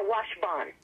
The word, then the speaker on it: Washbon: No, it's more Washbon.